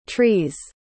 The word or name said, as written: tree's